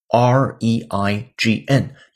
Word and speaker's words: reign: 是下雨那个单词吗？不是，同音异形词。今天这个词是 r e i g n，也读成 reign。